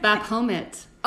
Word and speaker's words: Baphomet: Baphomet. Oh, I said it right.